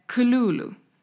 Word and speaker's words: Cthulhu: men det är Kululu. Så att mycket mer H i.